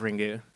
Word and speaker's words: Ringu: But the way that Japanese is written, you can't just have you have to have either a vowel or an N sound at the end of a word. So based on writing Ring in hiragana, it's pronounced, or katakana, it's pronounced Ringu.